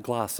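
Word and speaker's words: Glas: A guy by the name of John, we would say Glass. They pronounce it Gloss in Scotland.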